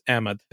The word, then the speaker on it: Ahmed: A lot of people say Ahmed, but it's actually like Ahmed is the Pakistani Ahmed way of saying it. Yeah, Ahmed is also good. That's usually what people with like more of an American accent end up settling on, which is great. So it's Ahmed is how it's pronounced.